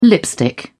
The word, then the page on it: lipstick: British English